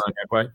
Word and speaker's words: Onyekwe: And I wanted to ask, you know, you had some notable names on that roster, like obviously yourself, but tell me if I'm pronouncing the name right. Onyekwe, is that how you say his last name? – Yep. You got Onyekwe.